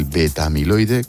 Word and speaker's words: beta-amiloide: ¿Cómo actúa el beta-amiloide, creo que lo digo bien, en nuestro cerebro? Sí. – Lo dice muy bien.